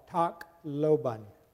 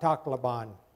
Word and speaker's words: Tacloban: As we represent you in the island of Leyte in the Philippine Islands, we're in the city of, now the correct way to pronounce this, at least where I live, is called Tacloban, not Tacloban, okay, because that's what you heard on the news here.